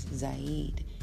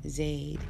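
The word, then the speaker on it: Zayd: I've been reading this book so far, pronouncing a name of one of her friends, spelled Z-A-Y-D. I've been pronouncing that as Zaid. That's the way I've known or heard it said in my world. But this is her world. This is her words. This is her book. That was her friend and her comrade. And I watched a few videos where she spoke about some things. And she pronounces his name Zaid out of respect for their way of saying his name.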